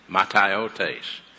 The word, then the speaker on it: mataiotes: M-A-T-A, mataiotes. M-A-T-A-I-O-T-E-S, mataiotes. And that would actually be a long E right there. M-A-T-A-I-O-T-E-S, mataiotes.